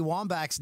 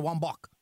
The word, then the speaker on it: Wambach: When did uh, when did the pronunciation of uh, Abby Wambach's name change to Wambach? – Wambach. No, as soon as we got up here, for some reason, I think it's the French inclusion uh, in in uh, in Canada.